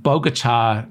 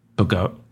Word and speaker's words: Bogota: Back in 2019, the senator's then-girlfriend, soon to be co-defendant for life, was speeding through the streets of Bogota, New Jersey, and it's pronounced Bogota, not Bogota. No, hold it. It's pronounced Bogota, New Jersey.